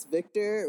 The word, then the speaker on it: Viktorh: It's Victor with a K and no C. And uh, recently, there's been a silent H added to the very end of that. uh, doesn't change the pronunciation.